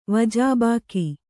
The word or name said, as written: ವಜಾಬಾಕಿ